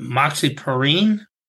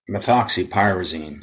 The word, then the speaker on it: methoxypyrazine: I'm going to butcher it, but moxipyrene? – Methoxypyrazine.